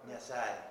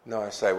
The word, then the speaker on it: Nyasae: Now, how do you pronounce that word, Nyasae? Is that right? – Nyasae. – Nyasae.